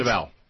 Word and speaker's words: Deval: Am I saying the name right? – Deval.